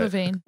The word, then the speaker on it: Levine: Levine.